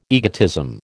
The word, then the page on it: egotism: American English